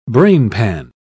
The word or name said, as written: brainpan